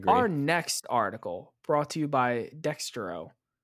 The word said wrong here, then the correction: Dexerto: Dexerto. – Is it? – Dexerto. I say Dexerto.